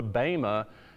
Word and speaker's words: bema: It's spelled B-E-M-A, but pronounced bema.